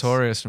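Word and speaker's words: Artorias: Yeah, the most complicated thing I've ever asked a quote on is like the Sword of Astorius. I don't know if I'm pronouncing that right. The one that the wolf wields in Dark Souls. – Artorius from Dark Souls. – Sword of Artorius.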